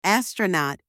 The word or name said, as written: astronaut